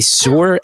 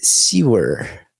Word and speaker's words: sewer: And I say sore, S-E-W-E-R. To this day, I think I say that wrong to 99% of America. – What's the right way? – They say like sewer. Like sewer.